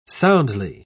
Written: soundly

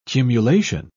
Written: cumulation

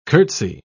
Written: curtsy

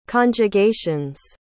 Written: conjugations

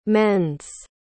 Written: mends

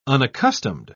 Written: unaccustomed

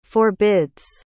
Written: forbids

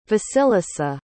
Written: vasilisa

